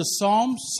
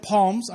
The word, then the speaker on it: Psalms: Okay, if you have a Bible, I want you to turn to Psalms chapter 130, or I've heard it said Palms. I'm not sure why people say that, but Psalms chapter 130, just so you know, the P is silent. It's random.